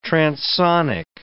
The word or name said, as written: transonic